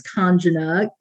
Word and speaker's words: Kanjana: So first name is Kanjana, K-A-N-J-A-N-A.